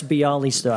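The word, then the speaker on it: Białystok: But I've done Krakow, I've done Poznan, I've done Wrocław, I've done Bielostock. I think that's how you pronounce it. – Oh, isn't that the character from The Producers? That's Bialystok.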